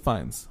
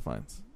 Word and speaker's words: Fiennes: Somebody's Joseph Phineas. Joseph Fiennes. I always say his name wrong. Joseph Fiennes.